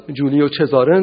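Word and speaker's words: Giulio Cesare: این آدم احتمالاً توی زبان ایتالیایی باید جولیو چزاره نامیده میشد، در زبان انگلیسی جولیو سیزر، در نمیدونم زبان آلمانی شاید یولیوس سزار، یا در زبان فرانسه ژول سزار.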